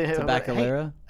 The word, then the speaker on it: Tabacalera: Agronosa Leaf, Drew Estate, Hoya de Nicaragua, Mambacho Cigars, Nicaraguan American Cigars, Romacraft Tobacco, and Tablica Aragon. I'm sure I'm saying that wrong. As with the Tabacalera? – Hey, Tabacalera. There you go.